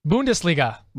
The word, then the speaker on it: Bundesliga: Bundesliga. – Bundesliga? – Start with dart at the paper.